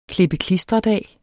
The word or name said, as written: klippe-klistre-dag